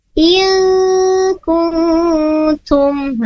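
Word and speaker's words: il kuntum (إن كنتم): Tadinya Ibu Karmi ini bacanya in kuntum, tapi yang tepatnya il kuntum.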